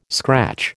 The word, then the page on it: scratch: American English